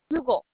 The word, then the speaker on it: Hugo: Hugo Martinez. – What would be the first name? – We both called together. – Hugo, okay. – Hugo, H-U-G-O, yes.